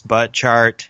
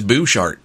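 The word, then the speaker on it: Butchart: I think it really is Bouchart. I think it's... – Bouchart. – Yeah. I think doesn't it kind of look like it's spelled butt chart? – It does, yeah.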